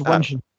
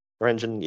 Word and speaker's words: Ranjan Singh: And he's got Ranjan Singh is how you pronounce it, I think. – I think it's Ranjan. – Ranjan, yeah.